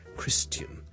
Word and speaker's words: Christian: 而基督徒 Christian 我哋上次讲咗，亦都有一啲又系老一辈嘅人可能会读成 Christian，嗰个发音好似 tian 咁，你当系好似人啦，跟住前面加个好轻嘅 t 音 tian，OK，咁但系呢个发音咧系唔需要学嘅，OK，咁但系咧听到就。